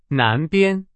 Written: 南边

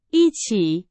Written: 一起

